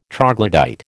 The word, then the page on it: troglodyte: American English